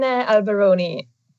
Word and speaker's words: Alberoni: And for that, we invited Caroline Alberoni.